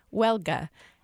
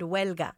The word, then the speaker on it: Huelga: It's called Welga. A, I hope I pronounced that semi-decently, and B, what does it mean? – You did wonderful. Huelga. Huelga is a Spanish word that is very, very popular in our community.